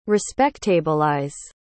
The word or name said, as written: respectabilize